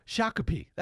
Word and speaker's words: Shakopee: Brad Tabke was declared the winner last week of the swing suburban how do you say it? Shakopee. I think it's Shakopee. – Okay. – Shakopee. That's it.